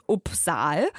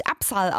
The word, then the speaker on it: Upsahl: Jetzt habe ich einen Song für euch von einer Künstlerin, die wird geschrieben U-P-S-A-H-L. Also wenn ich es jetzt einfach so ausspreche, wie man es liest, wäre das Uppsal. Das heißt, ich dachte irgendwie über so automatisch, das könnte jemand aus, weiß nicht, Skandinavien oder so sein. Ich finde, danach klingt das. Nee, ist aber ganz falsch, wird nämlich Uppsal ausgesprochen und das ist eine US-amerikanische Sängerin, die einfach Uppsal mit Nachnamen heißt.